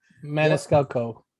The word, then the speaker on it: Maniscalco: And then Sebastian Maniscalco. – Maniscalco.